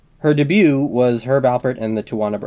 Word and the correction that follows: Tijuana: Brass. – It's Tijuana Brass. – Tijuana. I've always been mispronouncing.